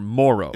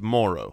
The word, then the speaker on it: Moreau: It's spelled Moreau, but no. – It's Foster Morrow. – It's Morrow. – Okay, the emphasis of the O is earlier in the right.